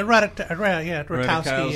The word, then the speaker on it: Ratajkowski: who's played by Emily Ratajkowski. Did I say her name right? – Yeah, Ratajkowski.